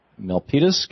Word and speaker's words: Milpitas: So Rob in Milpitas, California. Am I saying that right, Rob? – You are saying that right. Very good. Most folks get that one wrong.